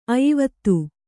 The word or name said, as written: ಅಯಿವತ್ತು